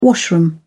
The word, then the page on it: washroom: British English